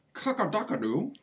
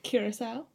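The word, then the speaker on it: Curaçao: It is, I say it wrong, but it's that blue kakadakadu. – Curacao.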